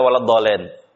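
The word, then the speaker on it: Waladhalin (وَلَا الضَّالِّين): Sama seperti waladhalin. Waladhalin. Perpindahan ke tasdid terus ditekan suaranya. Begitu cara bacanya. Itu namanya nabr namanya ya. Ada beberapa tempat dalam nabr dalam Quran. Itu diantaranya. Waladhalin. Ya enggak boleh orang baca waladhalin. Gitu ya, salah. Bacaannya salah. Ya.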